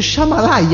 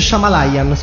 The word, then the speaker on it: Shyamalan: Si dice Shyamalan. – Ma non c'è una I. – Ho capito, ma si pronuncia così. Come io.